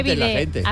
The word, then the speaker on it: bidet: Que no se dice bidet, se dice bidet.